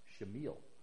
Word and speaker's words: Shemuel: Now Samuel, his name that she would name him, actually, I think it's interesting, the Hebrew name is pronounced Shemuel.